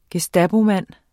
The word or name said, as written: gestapomand